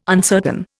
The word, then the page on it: uncertain: Indian